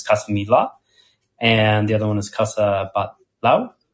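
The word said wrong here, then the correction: Batlló: Batlau or Batyau? – You're butchering it, but go for it. I don't know how to see it. – Casa Batyau. – No. Oh, there you go.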